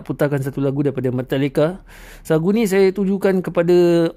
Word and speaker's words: Metallica: Pronunciation dia kena betul eh. Metallica, bukan Metallica, bukan eh. Mak-mak Melayu suka cakap Metallica, Metallica. Salah. Dia punya pronunciation is Metallica. Dia nak kena ikut American slang sikit. Cakap lagi sekali sama dengan saya, Metallica. Tu cara sebutan yang betul.